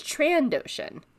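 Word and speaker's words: Trandoshan: It's transdotions. – I've always said it that way. – But it's trandotion with no S, trandotion.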